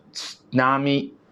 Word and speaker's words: tsunami: 然后呢他们的发音叫 tsunami。 tsunami 啊，euao 啊，日语当中有一个比较奇怪的这个发音叫 u 啊，英文当中呢，它只能用相似的那个 u 啊来代替。